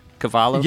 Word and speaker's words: Cavallo: Cavallo? – Yeah, you actually pronounced it correctly.